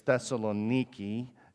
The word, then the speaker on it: Thessaloniki: or some people say it's Thessaloniki, if they want to pronounce it all Greek-y.